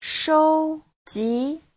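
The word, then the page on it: shouji: shou1ji2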